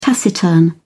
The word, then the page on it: taciturn: British English